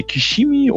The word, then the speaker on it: Kishimi: Und zwar äh, möchte ich gerne auch dann in dem Zusammenhang ähm, da ähm, das Buch von ähm, äh, Hiro Kashimi, ne, Kishimi und äh, Fumitake Konga.